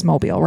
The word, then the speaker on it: Mobile: So in 2010, John DeBlase was a 27-year-old man from Mobile County, Alabama. It is Mobile, right? – Sure. – I'm sorry if I'm saying that wrong. – Mobile, Alabama. Yeah.